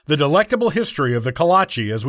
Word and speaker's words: Kolache: Let's start with the pronunciation, which is Kolache.